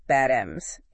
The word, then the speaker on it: Bad Ems: It's like the English word bat, Bad Ems.